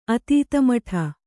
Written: ಅತೀತಮಠ

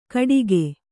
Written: ಕಡಿಗೆ

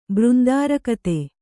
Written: ಬೃಂದಾರಕತೆ

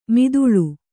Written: ಮಧೂಳಿ

